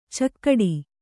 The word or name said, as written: ಚೌಗಡ